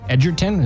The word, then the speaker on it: Edgerton: Edgerton, is that how you say it? – Yeah.